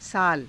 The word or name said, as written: saala